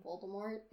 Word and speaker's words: Voldemort: It's like Jim Dale's pronunciation of Voldemort. – Oh, the fourth book, he's saying Voldemort. – Yeah, because that's the correct way.